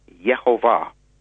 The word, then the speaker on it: Yehovah: When I asked him, he said, well, according to the oldest, most complete Hebrew manuscripts, the name would be pronounced Yehovah. Now, that's important because in Hebrew, there are subtle changes and subtle differences. So the Yeh is sort of like a half vowel, Yeh, the whole, and then the Va is where the accent is.